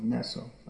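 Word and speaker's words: Nestlé: In English it's called Nestle. Yeah. Because we don't like to pronounce it like those frogs.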